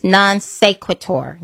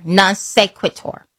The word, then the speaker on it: non sequitur: One of them is, I always mess up how to pronounce it, but it's non sequitur. Non sequitur. I would have to say it twice.